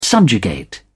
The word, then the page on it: subjugate: British English